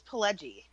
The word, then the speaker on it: Pileggi: I always say his name wrong because I say it as Mitch Pileggi, but is it Pileggi? – I believe it's Pileggi.